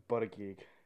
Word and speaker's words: Buttigieg: How do you pronounce it? Buttigieg, whatever. – It's Buttigieg.